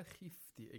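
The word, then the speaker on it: خفت: Fa'idha khifti. Again, the Kha should be pronounced with the lowest level of tafkhim. Khifti. We shouldn't say khifti. Khifti is incorrect because the point is with tafkhim,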